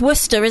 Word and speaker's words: Worcester: Now, they're playing on February the 9th against Worcester. Oh, God. Worcester? Worcester? It's Worcester, isn't it? Worcester.